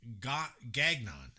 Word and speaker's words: Gagnon: Rosie Gagnon.